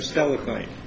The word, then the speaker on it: Steilacoom: And I hope, did I pronounce that right, Steilacoom or Steilacoom? – Steilacoom Lake. – Steilacoom. Steilacoom Lake Improvement Club versus State of Washington and a cast of many others.